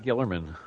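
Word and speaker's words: Guillermin: Gillerman? I don't know how to pronounce his name. I got to admit. – I pronounce it Gillerman. – Gillerman.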